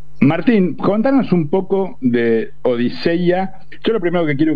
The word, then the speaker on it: Odisea: La que es más culturosa, es que es como se pronuncia en griego. Entonces es Odiseia.